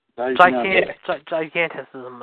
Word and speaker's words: gigantism: That's how you pronounce it, right? Gigantism? – Yeah.